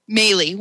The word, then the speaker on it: Maley: Is it Malley or Maley? – Maley, one L.